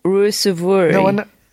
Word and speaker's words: Ruusuvuori: Ruusuvuori. – Ruusuvuori.